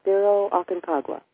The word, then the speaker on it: Cerro Aconcagua: Cerro Aconcagua.